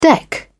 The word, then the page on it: deck: British English